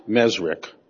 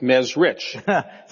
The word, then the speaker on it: Mesrich: Now, your last name is pronounced Mesrick, but it's spelled R-I-C-H. – Yeah. – Why don't you pronounce it Mesrich?